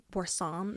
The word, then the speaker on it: Boursin: Have you guys ever had boursin or boursin? Is how it's pronounced.